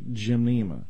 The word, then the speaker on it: gymnema: One thing that could work, there is an herb called gymnema that's spelled G-Y-M, N as in Nelson, E-M-A, gymnema.